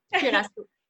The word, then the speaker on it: Curaçao: Donc, euh qui est en direct de, je vais sûre de bien le prononcer, Curaçao. Je l'ai... Curaçao. Curaçao. C'est parfait.